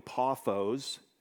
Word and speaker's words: Paphos: And when they had gone through the whole island, as far as, uh, there's several ways to say this, paphos seems to be the way, but really paphos is the correct pronunciation.